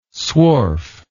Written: swarf

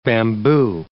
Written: bamboo

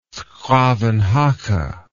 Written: 's Gravenhage

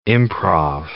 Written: improv

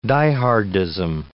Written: die-hardism

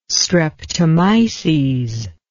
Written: streptomyces

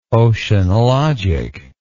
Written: oceanologic

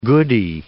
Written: goody

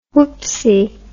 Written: whoopsy

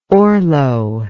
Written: orlo

